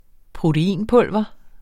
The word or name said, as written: proteinpulver